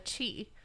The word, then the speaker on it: qi: Typically it's pronounced chi, but it's spelled qi.